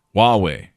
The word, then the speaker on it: Huawei: FCC bans carriers from using federal subsidies to buy equipment from Hawaii, which is actually pronounced... How do you pronounce Hawaii? Huawei. You pronounce it Huawei.